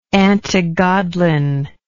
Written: antigodlin

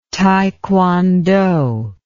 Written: taekwondo

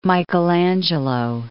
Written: Michelangelo

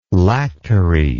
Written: lactary

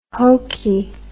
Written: hokie

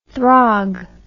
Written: throg

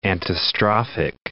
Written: antistrophic

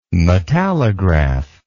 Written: metallograph

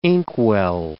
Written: inkwell

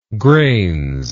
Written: grains

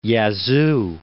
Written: Yazoo

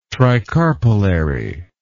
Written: tricarpellary

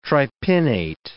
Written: tripinnate

